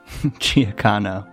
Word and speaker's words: Giacchino: Giacchino. – That's the way you pronounce it.